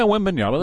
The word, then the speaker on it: Wembanyama: And Wembanyama, am I saying it correctly? – Yeah, Wembanyama. That's fine.